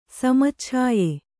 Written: ಸಮಚ್ಛಾಯೆ

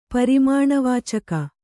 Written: ಪರಿಮಾಣವಾಚಕ

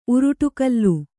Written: ಉರುಟುಕಲ್ಲು

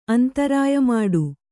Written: ಅಂತರಾಯಮಾಡು

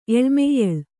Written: ಎಳಮೆಯ್ಯಳ್

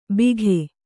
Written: ಬಿಘೆ

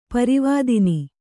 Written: ಪೂರ್ವದಿನ

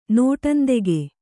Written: ನೋಟಂದೆಗೆ

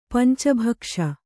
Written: ಪಂಚಭಕ್ಷ

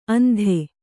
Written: ಅಂತೆ

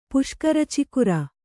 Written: ಪುಷ್ಕರಚಿಕುರ

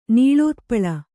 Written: ನೀಳೋತ್ಪಳ

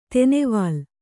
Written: ತೆನೆವಾಲ್

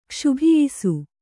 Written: ಕ್ಷುಭಿಯಿಸು

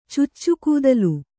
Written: ಚುಚ್ಚುಕೂದಲು

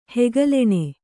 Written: ಹೆಗಲೆಣೆ

